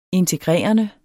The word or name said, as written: integrerende